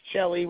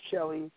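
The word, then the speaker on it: Celli: C-E in Italian is pronounced Chelly, Chell. So it's Kevin Chelly,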